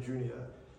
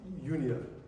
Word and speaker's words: Junia: And it actually probably not pronounced Juni, but it's probably like Junia there.